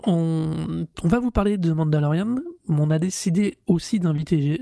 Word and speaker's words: Mandalorian: C'est comme ça ça se prononce c'est, The, Mandalorian. Ouais, Mandalorian.